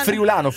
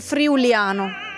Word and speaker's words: friulano: Friulano. – No, l'errore di tutti è dire friuliano.